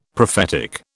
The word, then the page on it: prophetic: British English